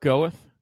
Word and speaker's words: Gawith: Am I saying that right? – Marc Gawith. – Gawith. Okay, there you go.